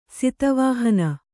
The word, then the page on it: ಸತ್ವಹೀನೆ: satva hīne